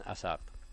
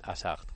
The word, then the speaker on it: Hazard: Eden Hazard. – Dilo otra vez, por favor. – Eden Hazard.